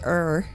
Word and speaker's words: Ur: So, you're with... Is that just your or is it er? – Er.